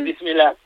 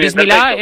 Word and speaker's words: Bismillah: Una sí, cosa que me dijo Iria Sacomag, a ver si la pronuncio bien, Bismillah. – Eso es. – ¿Lo he dicho bien? – Bismillah. Sí, muy bien, muy bien. – Bismillah, perfecto.